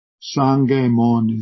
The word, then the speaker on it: Sangemon: Sangemon is the Sino-Japanese way of pronouncing the text.